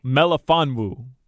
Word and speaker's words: Melifonwu: I don't know if I'm saying his name right. – You're not. – Like, we'll see if he can replace – I'm not. Belafondu, what's his name? – No, Melifonwu. Melifonwu.